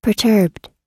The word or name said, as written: perturbed